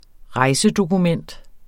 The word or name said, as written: rejsedokument